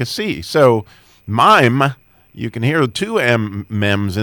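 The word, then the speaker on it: mime (מים): So just to give us a highlight of more Mem, since we're in the Mem section, the word mime is how you would pronounce water in Hebrew, and it starts out with an open mem, because there's two different kinds of mems.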